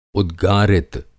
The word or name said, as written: उद्गारित